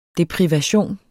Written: deprivation

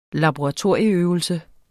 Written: laboratorieøvelse

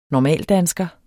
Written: normaldansker

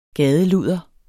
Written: gadeludder

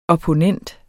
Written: OPPONENTENS

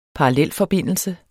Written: parallelforbindelse

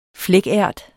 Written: flækært

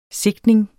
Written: sigtning

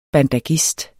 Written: BANDAGIST

